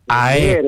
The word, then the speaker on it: ΑΕΚ: Ναι, μα είπαμε να τονίζει το έψιλο, όχι το αρφα, το έψιλο. ΑΕΚ.